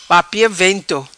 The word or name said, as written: Papia vento